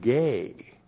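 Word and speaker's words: gay (γῆ): Cosmos, occasionally, sometimes is a synonym for the Greek word gay. G, long E, and that is the word that means earth or planet earth.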